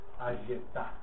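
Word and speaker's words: Ayetá (היתה): Se pronuncia así. Esta es la letra, se puede decir A, Y, Ta. Así se pronuncia. Ayetá.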